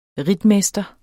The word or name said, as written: ritmester